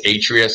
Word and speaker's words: Atria: So you mispronounced it. I'm not sharpshooting, but it's Atria Senior Living.